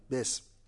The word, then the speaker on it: بسم: بله بر سر سین در میاد بسم، الف دیگه نیست، الف دیگه نیست. بسم الله الرحمن الرحیم اگرچه با حرف جر بر سر اسم در میاد و اسم الف دارد، ولی این الف نه در قرائت تلفظ می شود نه در کتابت در واقع نوشته می شود.